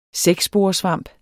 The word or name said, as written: sæksporesvamp